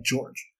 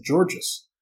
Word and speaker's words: Georges: Luke McClure just says, as an Iowa State alum, I am required to let you know that the S in George Niang is silent and is just pronounced like George.